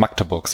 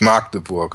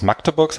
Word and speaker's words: Magdeburg: Du musstest auch, äh, um dich mit den lokal, äh, lokalen Menschen nicht komplett über, zu überwerfen, musst du es, glaube ich, auch schnell aussprechen. Du musst Magdeburg sagen. Nicht Magdeburg. Genau, du musst Magdeburg sagen. Und ich war zweimal, glaube ich, da.